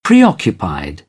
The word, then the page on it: preoccupied: British English; ɒ